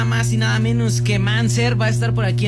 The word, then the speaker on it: MNZR: Es Manser, pero se escribe M-N-Z-R. – Ok. – M-N-Z-R Manser. – Manser.